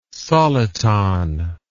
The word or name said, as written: soliton